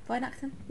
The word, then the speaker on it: Weihnachten: Did I say that right? Weihnachten? Woo! Yeah, yay!